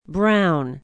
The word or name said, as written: Brown